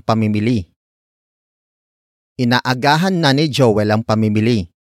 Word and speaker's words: inaagahan: Inaagahan na ni Joel ang pamimili, inaagahan na ni Joel ang pamimili.